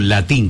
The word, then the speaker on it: latín: No, latín, con acento en la I, sí, no sí. En la latín. Vos dijiste latín.